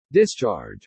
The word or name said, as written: discharge